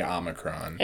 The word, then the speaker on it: Omicron: What do you mean? – It's not Omicron? – Mm-mm. It's Omicron. – Oh. – So is this like a pedantic, like, this is how Greek words are pronounced mm-hmm. thing? Or is this like actually like when you say it, you should say Omicron? – It's Omicron. – It is Omicron.